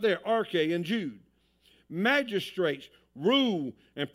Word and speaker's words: arche: It is the Greek word arche, pronounced arche, and it literally means a commencement or a beginning of something, the origin of something.